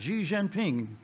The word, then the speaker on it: Xi Jinping: Xi Jinping, president of China.